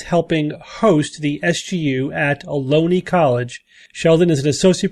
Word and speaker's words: Ohlone: And the Psychology Club and the Bay Area Skeptics, I guess, are co-hosting our event at Ohlone, which you tell me rhymes with baloney.